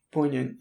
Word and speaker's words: poignant: Poignant? Am I saying that right? – Poignant, yeah.